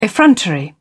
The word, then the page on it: effrontery: British English